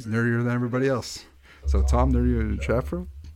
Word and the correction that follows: Chatfield: Well, I butchered that. Tom, nerdier than you are, Chatfield.